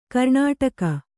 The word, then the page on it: ಕರ್ಣಾಟಕ: karṇāṭaka